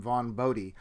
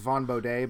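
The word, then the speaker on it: Vaughn Bodē: So, for example, there is an underground cartoonist named Vaughn Bodie. And for those who think I'm saying it wrong, I met his son once. That's how he pronounced it. So whatever. Most people think it's Vaughn Bodie, but he said his name was Mark Bodie.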